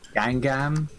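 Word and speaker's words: Guingamp: He's been at quite a few clubs, but yeah, last season he played 35 of the 38 games with 30 starts for Gwingamp, or how did you say it? – Gwingamp.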